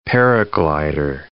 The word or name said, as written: paraglider